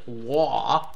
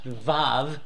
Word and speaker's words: vav: So if you've ever wondered, for example, what I would not pronounce it wah over top of verse 41 there. It's more properly, at least the way I was taught, pronounced vav.